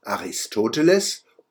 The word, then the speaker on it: Aristoteles: Die deutsche Aussprache Aristoteles und Aristophanes ist nicht falsch. Sie entspricht der Klanggestalt des Deutschen. Die beiden Griechen heißen so auf Deutsch.